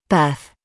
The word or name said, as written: birth